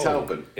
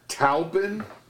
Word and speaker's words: Taupin: I never know how to pronounce his last name. Taplin? The famous songwriter for Elton John. – Oh, Bernie Taupin.